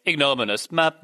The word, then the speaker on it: ignominious: But I also think, you pronounce it, by the way, it's ignominious.